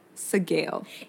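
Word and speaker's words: Sgaeyl: Sagale. It's like scale, but with a G.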